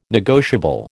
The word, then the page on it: negotiable: American English